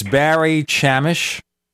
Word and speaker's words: Barry Chamish: So, it's Barry Chamish.